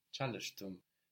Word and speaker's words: Çalıştım: Çalıştım. – Çalıştım. So, the ş and the ç are hard consonants.